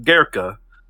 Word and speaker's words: Goeke: Was, first of all, his name is just fun to say because it's spelled Goeke, but it's pronounced Gerka.